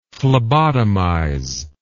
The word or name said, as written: phlebotomize